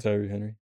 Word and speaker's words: Tyree: Tyree Henry? Cool as Lemon. – Yeah, Tyree Henry.